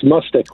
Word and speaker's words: Mustich: It's Mustich, one. – Mustich. Mustich. Mustich. Like mustard, but not. – Correct. With a K on the end.